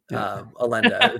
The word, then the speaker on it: Elenda: Saucy, it's spelled E-L-E-N-D-A, Alenda, if you want For your R34 search.